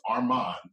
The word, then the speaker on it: Armand: So today I brought a track by the name of Immortal by Chief Armand. It's spelled A-R-M-A-N-D though, not A-R-M-O-N, because I'm unique.